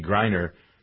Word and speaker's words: Griner: He's working on trying to get Brittany Griner, that's how we pronounce her. – Griner, I always ask you that, from the Russian prison.